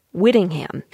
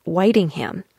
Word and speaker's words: Whitingham: Like, there's one town that on paper looks like Whittingham, but it's actually said Whitingham. – Whitingham has an emphasis on the first syllable, white. Whitingham.